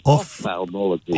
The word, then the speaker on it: ophthalmology: It's spelled D-I-P-H-T-H as diphtheria. And then I couldn't believe it that an ophthalmologist said that incorrectly, as I'd just purposely done. It's spelled also like diphtheria, O-P-H-T-H. So it's ophthalmology.